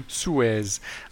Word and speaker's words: Suez: Has Jameis one of one figured out how Jameis can unblock the Suez Canal? I don't know if that's how you pronounce Suez Canal in English. – Suez. I would say Jameis one of one.